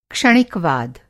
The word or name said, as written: क्षणिकवाद